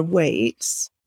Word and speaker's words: Waights: My handle is actually at Sarah Waits. That might be my real name. And it's spelt W-A-I-G-H-T-S.